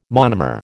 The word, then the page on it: monomer: American English